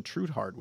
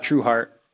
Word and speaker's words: Trueheart: I'm excited to have Jason Trueheart with me. Did I pronounce that right, Jason? – True heart, like just true heart.